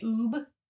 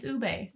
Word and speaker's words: ube: You've been pronouncing it oob? – What if it is oob? – It's ube. – Rhymes with boob.